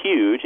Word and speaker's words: huge: I think a really great one, speaking as a linguist, is the word huge, which is huge, H-U-G-E, with a Y sound at the beginning instead of the aspirated H.